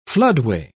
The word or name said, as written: floodway